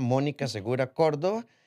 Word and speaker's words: Mónica Segura Córdoba: Un curso solo para mujeres y se pueden buscar la información en las redes sociales de la doctora Mónica Segura Córdoba, eh, tanto en Instagram como en Facebook. Eh, ¿Lo dije bien? – Exacto, súper bien.